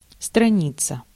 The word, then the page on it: kant: /kɑnt/